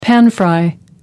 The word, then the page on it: pan-fry: American English